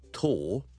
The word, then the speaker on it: tall: 上一集咧，我哋讲咗 tall 呢个字嘅发音 T A L L。我哋今日咧讲多几个同佢系押韵嘅字，因为咧个发音都系一样嘅。我哋讲 tall 两件事咧，个 vowel 系 o o o 嘟少少，嘴里边口腔里面好多空间嘅 tall tall o o。